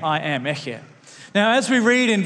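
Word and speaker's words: Eche (אֶהְיֶה): You can pronounce this word, Eche.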